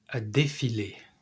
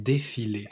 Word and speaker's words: défilé: How do you say that? – A défilé. Un défilé. – A defile.